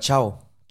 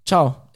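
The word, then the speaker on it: Thiaw: Malik Thia, wie heißt er wieder ausgesprochen, von Mailand? – Mike Ciao. – Ciao.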